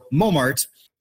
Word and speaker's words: Montmartre: So Montmartre.